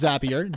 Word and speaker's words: Zapier: Anyways, uh, it is pronounced Zapier. Danny's correct. I was on the horn with the CEO of Zapier, and uh, I asked him. I'm like, bro, I always forget. Is it Zapier or Zapier? And he goes it's Zapier. Like Zapier makes you happier.